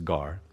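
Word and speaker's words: gar (γάρ): It's the Greek word gar. We pronounce it as gar.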